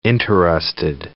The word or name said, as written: interested